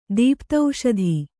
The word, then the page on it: ದೀಪ್ತೌಷಧಿ: Kannada